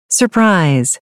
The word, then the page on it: surprise: American English